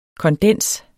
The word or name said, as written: kondens